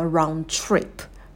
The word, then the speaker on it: round trip: Поездка туда и обратно – это round trip. Round trip. Также произносим без звука «д», и в слове trip появляется звук «ч», когда у нас вместе идут «т-а», то вот это вот такое шипение будет присутствовать. Trip, trip, round trip.